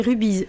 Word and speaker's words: Rubies: D'abord Rubies, on prononce Rubies, hein, c'est ça? – C'est Rubies, rugby union bien-être et santé.